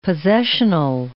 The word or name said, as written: possessional